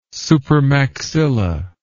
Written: supermaxilla

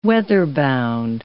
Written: weather-bound

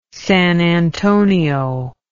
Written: San Antonio

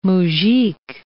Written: moujik

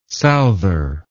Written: souther

